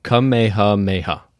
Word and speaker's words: Kamehameha: Kamehameha.